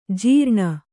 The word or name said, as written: ಝರಣಿ